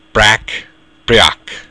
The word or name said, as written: breac